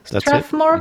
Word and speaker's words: Strathmore: But the one that I stuck with right now is Strathmore, you pronounce? – Yes, Strathmore, that's it.